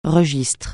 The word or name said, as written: registre